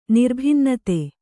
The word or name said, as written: ನಿರ್ಭಿನ್ನತೆ